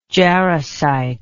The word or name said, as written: jarosite